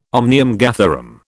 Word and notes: omnium gatherum: British English

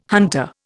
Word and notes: hunter: Indian

